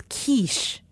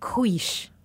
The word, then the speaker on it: quiche: However, the word is quiche, not quiche.